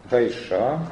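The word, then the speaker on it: Хаиша (האישה): Иша ударение. Хаиша.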